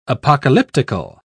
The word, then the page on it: apocalyptical: British English